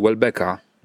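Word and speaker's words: Houellebecq: Ale ponieważ, jak sądzę, nie wszystkim naszym słuchaczom nawet postać Welbecka może się nawet nie zorientowali, o kim mówimy, bo tak to wymawiamy ładnie po francusku.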